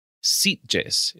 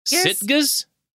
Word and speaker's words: Sitges: The only non-Brazilian festival where a Sete Vampiras definitely played was Sitges. Is that how you pronounce that in Spain? – Sitges? – I always want to say Sitges. – Sitges. – Whatever. El Sitges in Spain.